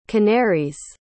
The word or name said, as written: canary's